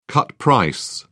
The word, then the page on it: cut price: British English